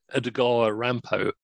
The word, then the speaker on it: Edogawa Rampo: He was sort of the sort of wonderkind of Japanese literature, and his he wrote under this name Edogawa Rampo, which is almost impossible to pronounce in properly, because if you if you can speak any Japanese, it reads like reads as Edgar Allan Poe um, who he worshipped.